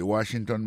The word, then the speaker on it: Washington: Si nous mettons accent sur accent encore, on va voir que mot Washington, l'accent tombé sur le premier syllabe là. Pour mot monument, il tombe sur le premier syllabe là. The Washington Monument.